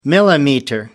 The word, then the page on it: millimeter: British English